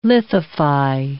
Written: lithify

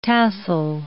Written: tassel

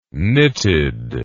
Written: knitted

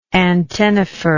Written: antennifer

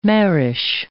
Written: marish

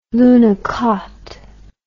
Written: lunokhod

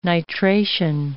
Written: nitration

